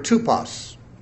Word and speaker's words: tupos: The Greek word tupos, we would spell it T-U-P-O-S, tupos.